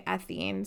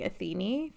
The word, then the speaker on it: Athene: So I've seen different pronunciations of this. Some say Athene, some say Athene, the goddess of war and wisdom.